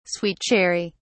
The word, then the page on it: sweet cherry: British English